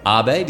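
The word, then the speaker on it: ağabey: Abe, did you say? – Abe. – So you don't pronounce the G? – It's a soft G, abe.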